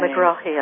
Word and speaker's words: Megrahi: Megrahi, I think, is how it's pronounced.